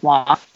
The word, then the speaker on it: Roy: I'm assuming this kid whose last name is spelled R-O-Y is seeing his Wah. – Yeah. – Last Sunday, Nicholas Wah of the Vegas Golden Knights scored against the Ducks in a 5-2 Knights win.